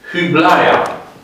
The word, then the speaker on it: Hyblaia: Hyblaia. H-Y, când pronunț cu U, înseamnă că veți nota cu I grecesc. Când pronunț cu I, putem scrie fără grijă I.